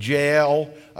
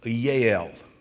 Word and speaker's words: Jael: We would pronounce it Jael. A Hebrew would pronounce it Yael.